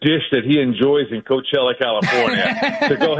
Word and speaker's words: Coachella: and then Coachella. Is that how you say it? Coachella? Is that? – That's exactly right. Coachella, California.